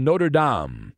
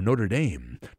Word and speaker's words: Notre Dame: a highly decorated player from Notre Dame, or Notre Dame, depending how you say it.